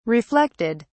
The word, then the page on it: reflected: British English